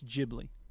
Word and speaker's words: Ghibli: So in any case, the first syllable is pronounced G, so it's Ghibli.